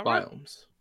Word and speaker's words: biomes: Minecraft Never Update adds new biomengs? Biomengs? Is that biomes. – Right? Biomes? Okay.